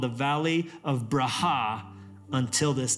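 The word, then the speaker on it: Braha (ברכה): How you say that word in Hebrew is Braha. Braha. B-R-A-H-A-H is like the pronunciation. Braha. You have to say it like with surprise.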